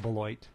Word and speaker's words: Beloit: A college list called, I don't know how to pronounce that. It's French, I imagine. Belois or Beloit College. – I call it the Beloit.